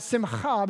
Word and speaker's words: simcha: The Hebrew word that's used here for joy in Nehemiah 8 is simcha. And I say it like that, simcha, because I think if you pronounce it the right way, you get more joy, okay?